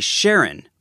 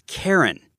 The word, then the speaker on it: Charon: Most spectacular of these moons would be Charon, also known as Charon. It's spelled C-H-A-R-O-N, and it's unfortunately pronounced both ways, depending on who you ask.